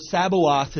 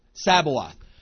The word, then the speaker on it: Sabaoth: Sabaoth. I hear that, and I immediately think Sabbath. Because the spelling is similar, the pronunciation is similar. It's not the same.